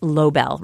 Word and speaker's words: Lobel: I've learned that firsthand at Revisionist History, where I've had the benefit of some incredible colleagues, like my producer Mia LaBelle, who's the reason Revisionist History is so amazing. – You know it's actually pronounced Lobel, right, Malcolm? – But how do you find people like Mia?